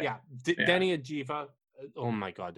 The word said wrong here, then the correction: Avdija: Just say Avdija. Think of it like you're Brad Pitt and Inglourious Basterds and just say it phonetically, and it works. Avdija.